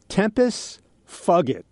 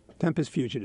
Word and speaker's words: tempus fugit: Tempus fugit. No, I know that's not how you say it. Tempus fugit. Tempus fugit is how you say it.